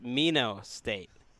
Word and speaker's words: Minot: What do you think about the theory or fact that it's pronounced Mino State? The T is silent.